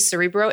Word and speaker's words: Cerebro: But I have like my main obsession is definitely Cerebro. Am I saying it right? – Yes. You are.